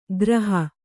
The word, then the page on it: ಗ್ರಹ: graha; Kannada